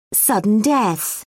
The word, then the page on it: sudden death: British English